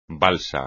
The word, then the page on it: balsa: espagnol